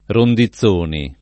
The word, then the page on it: Rondizzoni: lingua italiana